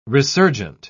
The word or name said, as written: resurgent